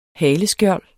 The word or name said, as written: haleskjold